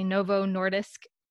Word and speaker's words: Novo Nordisk: Novo Nordisk Innovation.